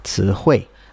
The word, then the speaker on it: 词汇: 词汇. – Right, so it has rising tone 词, and this falling tone 汇. And some of my students struggle with the rising part. They would usually pronounce it as a first tone, like 词汇, or something. But yeah, some people struggle with that as well.